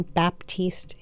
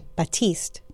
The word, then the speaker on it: Batiste: For all the people who proclaim to love him, please stop calling him John Baptiste. He is not. Or just spelling it that way. Batiste. Show the man respect. Get his name right.